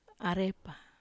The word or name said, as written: arepa